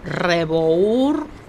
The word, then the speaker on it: Rebour: Yo soy Patricia Rebour y mi apellido se escribe Rebour con B larga, como le decimos.